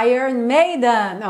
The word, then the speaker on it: Iron Maiden: Como é que fala mesmo o nome dessa banda? Iron Maiden, ok? Agora você já tá caprichando aí no Iron Maiden.